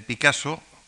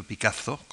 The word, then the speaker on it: Picasso: porque lo mismo se puede pronunciar, hay algunos que cecean y otros que sesean, así que el Picasso o Picasso con dos S,